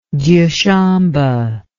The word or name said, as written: dyushambe